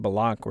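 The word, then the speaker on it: Blalock: Am I saying that right name, Bullock, or is it my Appalachia education? Okay, Luna's. Okay, so it's right.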